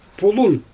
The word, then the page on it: pulun: Xyzyl